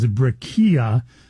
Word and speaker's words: Brekiya: Okay, so I'm pronouncing it wrong. It's Brekiya, and it's spelled B-R-E-K-I-Y-A.